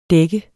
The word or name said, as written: dæk